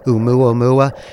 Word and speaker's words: Oumuamua: O-U-M-U-A-M-U-A.